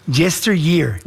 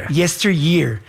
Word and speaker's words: Yesteryear: Caro Claire Burke, se pronuncia Yesteryear. – Yesteryear.